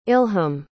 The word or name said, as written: ilham